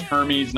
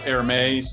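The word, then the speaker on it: Hermès: Like I always thought it was Hermes, not Hermes.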